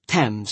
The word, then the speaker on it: Thames: which sounds like Thames if you're speaking British, by the way.